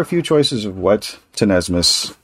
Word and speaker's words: tenesmus: First word is, Tenesmus. – Tenesmus. – Tenesmus. That is spelled T-E-N-E-S-M-U-S, and it is a noun.